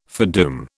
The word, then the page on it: foredoom: British English